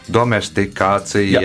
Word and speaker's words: domestikācija: Tā es tagad pareizi izrunāšu. Dom, domesticēšana tālāk domestikācija, Jā.